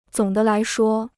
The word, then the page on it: 总的来说: zǒng de lái shuō